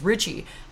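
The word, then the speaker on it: Ritchey: But I know I would have dealt with that exact same thing, because my last name is Richie.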